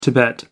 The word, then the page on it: tibet: American English